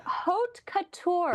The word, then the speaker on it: haute couture: or home couture, or whatever that word is that you just pronounced a little while ago. – Haute couture, in the fashion world.